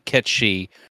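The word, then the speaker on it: Cait Sith: The other pronunciation for Kate Sith is Ketshi, which is what is pronounced. It's pronounced that way in the Japanese version,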